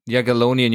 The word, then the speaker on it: Jagiellonian: Jagiellonian University in Krakow.